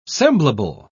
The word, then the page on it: semblable: British English